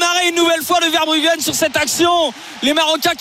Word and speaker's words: Verbruggen: Alors je vais pas te le dire avec la prononciation néerlandaise sinon je vais te complexer, mais comme tu dis Verbruggen, Verbruggen, il y a pas de gueux au Pays-Bas, c'est Verbruggen.